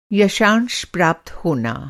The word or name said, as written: यथांश